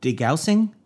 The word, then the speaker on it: degaussing: But it was it wasn't technically invisible by the use of degaussing. I don't know how to pronounce it. – D-E-G-A-U-S-S-I-N-G.